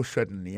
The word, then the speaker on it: certainly: pour un mot certainly accent tombé sur premier syllabe là yes certainly témoin point pour encore yes certainly comprenez accent tombé sous premier syllabe là.